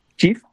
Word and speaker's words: Cheaf: Por supuesto, Chief, que se escribe CHEAF, de nuevo, CHEAF, uno la puede descargar de su celular.